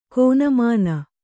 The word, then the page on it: ಕನಿಮನೆ: kanimane